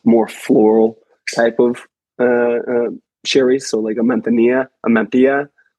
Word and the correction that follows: manzanilla: or a manzanilla. – There you go. That's the pronunciation. – There we go. That's the right word.